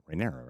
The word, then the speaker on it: Rhaenyra: So you have that with Aegon Targaryen, but they really show that with Rhaenyra. I always get confused on how to pronounce it. Rhaenyra, right? – Yeah.